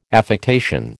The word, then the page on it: affectation: American English